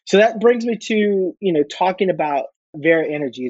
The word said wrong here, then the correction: Varia: Am I pronouncing that right? Vera Energy? – It's Varia. – Varia. – Virtual and area. So Varia.